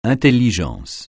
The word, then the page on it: intelligence: francais